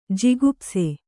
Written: ಜುಗುಪ್ಸೆ

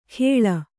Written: ಖೇಳ

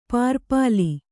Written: ಪುರ್ಪಾಲಿ

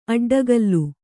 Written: ಅಡ್ಡಗೆಲ್ಲು

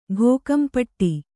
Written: ಘೋಕಂಪಟ್ಟಿ

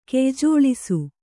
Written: ಕೆಯ್ಜೋಳಿಸು

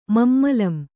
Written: ಮಮ್ಮಲಂ

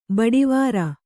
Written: ಬಡಿವಾರ

